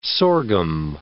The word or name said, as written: sorghum